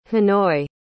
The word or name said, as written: hanoi